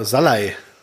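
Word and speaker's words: Szalai: ja, und zwei Treffer von Schalai, ne? Äh, ja, genau, Salai. Oder Salai, ja, sorry. – Ja. Falsch ausgesprochen. Genau.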